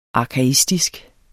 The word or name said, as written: arkaistisk